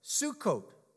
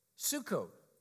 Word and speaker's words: Sukkot: It's pronounced the Feast of Sukkot. Sukkot, which means booths.